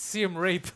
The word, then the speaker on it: Siem Reap: He's mispronouncing it. It's Siem Reap.